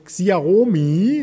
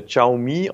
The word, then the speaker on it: Xiaomi: Xiaomi, ist das richtig? – Ja, ich äh, habe mal gehört, dass es äh, Xiaomi ausgesprochen wird.